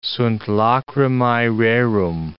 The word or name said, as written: sunt lacrimae rerum